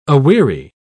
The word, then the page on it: aweary: British English